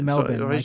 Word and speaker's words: Melbourne: They say it Melbourne. – Sorry, like M-E-L-B-I-N.